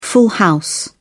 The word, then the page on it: full house: British English